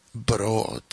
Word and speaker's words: Brood: Y si alguien quiere ver más información de él, Herman, se escribe tal cual, H-E-R-M-A-N, y el apellido es B-R-O-O-D, Broad, Broad.